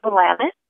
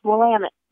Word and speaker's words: Willamette: Locals, we pronounce it Willamette. And one of the ways, yes, and one of the ways that the tour companies have you remember that is they say, it's Willamette, damn it.